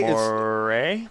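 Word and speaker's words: moiré: Moire? It's a French term meaning it looks like shit, okay?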